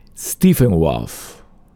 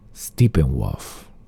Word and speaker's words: Steppenwolf: Stephen Wolf. – Perfecto, yo, así. Ah, y me podría hacer una que diga The Police. – Ya déjame pronunciar esta de nuevo. Steppenwolf.